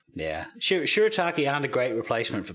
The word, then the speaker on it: shirataki: But it was just like a normal dinner, and I had some, I have no idea how to say this word, shirataki, is that how you say it? – Yeah, that's right.